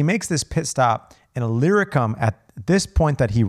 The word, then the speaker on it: Illyricum: From Jerusalem, he says, from there all the way to, I'm going to cover, he says, all the way around to Illyricum.